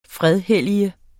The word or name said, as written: fredhellige